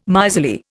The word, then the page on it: miserly: Indian